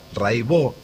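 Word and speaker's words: Raybaut: Se escribe Raibaus, métanse, búsquenla. Rai con Y, Baut con B larga. Catalina Raibaut, Raibó se dice.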